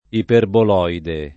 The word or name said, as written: iperboloide